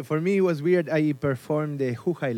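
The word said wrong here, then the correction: Houhai: Lake in Beijing. – Hohai. – Area. Yeah. – Yeah. – And you just corrected my pronunciation in Chinese, which I don't give a shit. So, I'm joking. – It's Hohai Lake.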